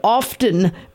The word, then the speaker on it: often: When did often become acceptable? It's always been often. The T is silent.